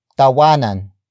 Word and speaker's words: tawanan: For example, tawanan ng problema. Tawanan ng problema. Tawanan ng problema. It means laugh at the problem. The focus is on the problem. Word for word it's tawanan ang problema.